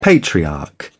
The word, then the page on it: patriarch: British English